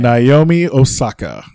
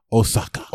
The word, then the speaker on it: Osaka: Naomi Osaka. Naomi Osaka.